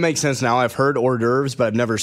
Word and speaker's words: hors d'oeuvres: Hors d'oeuvres is how you pronounce the other one.